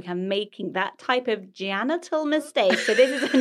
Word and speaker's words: Jannatul: I met a lovely lady called Janital. – Oh. – J-A-N-N-A-T-U-L. – Oh. – Janital.